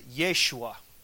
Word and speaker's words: Yeshua: If you know if you study the language of Hebrew, do you know how you say Jesus in Hebrew? You pronounce it Yeshua. What does that sound like? Sounds like Joshua. Because Joshua and Jesus in Hebrew are pronounced and spelt exactly the same.